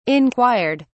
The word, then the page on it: inquired: British English